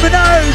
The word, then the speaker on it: Minogue: It's Kylie Minogue.